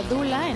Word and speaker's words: doula: Doula es una palabra griega que bueno, se pronuncia doula en griego.